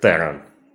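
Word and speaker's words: Theron: Да, фамилия произносится именно как Террон, если...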